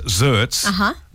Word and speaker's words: xertz: Well, it's pronounced XERTZ, but it's spelt X-E-R-T-Z.